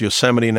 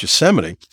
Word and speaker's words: Yosemite: Trump, on the other hand, pronounced Yosemite National Park as "yo semite." Think about that. Trump is not smart enough for the job and it showed in his performance. First of all, not knowing how to pronounce, Yos- you know, pronounce Yosemite. Now, who amongst us did not watch watch Looney Tunes when we were kids and watch Yosemite Sam on Bugs Bunny? That's how everybody learned how to pronounce Yosemite.